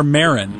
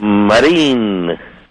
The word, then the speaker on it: Marin: It's just the English version is Marin, and the correct Spanish pronunciation is Marine. Actually, it's a French name, Marin, which is French for sailor.